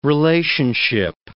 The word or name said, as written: relationship